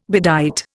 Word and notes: bedight: Indian

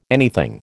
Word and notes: anything: American English

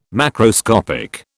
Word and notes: macroscopic: British English

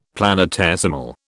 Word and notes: planetesimal: British English

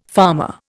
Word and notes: farmer: Indian

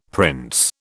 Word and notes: prince: British English

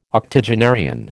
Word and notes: octogenarian: American English

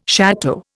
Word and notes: château: Indian

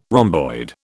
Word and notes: rhomboid: British English

